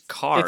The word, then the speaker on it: Kerr: Because he says Carr. – It's spelled Kerr, it looks like, but he said Carr.